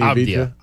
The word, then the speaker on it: Avdija: But I know that you love, and they love, Denny of Dija. How come I can't ever say his name? Denny Avdija.